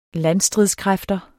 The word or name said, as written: landstridskræfter